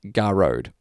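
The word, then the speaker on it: Gearóid: it is, as one of your listeners pointed out, correctly pronounced Garode. – Garode. – Garrode.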